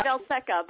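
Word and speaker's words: Veselka: How do I pronounce that? I haven't said it in years. – I pronounce it Velseca, but yes, that's why I put on the pierogi song.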